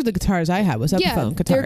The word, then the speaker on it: Epiphone: But when people started buying guitars in April, they ended up reopening the guitars, relaunching the Epiphone. I think that's how you pronounce it. – Yeah, Epiphone.